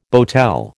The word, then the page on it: boatel: American English